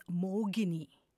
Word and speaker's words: Mohini: In the Tamil language, Mohini, H, is written or pronounced as Mogini. So it can be a little confusing when we're reading it in English, but it is Malligai Mohini.